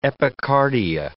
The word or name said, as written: epicardia